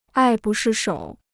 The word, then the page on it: 爱不释手: ài bù shì shǒu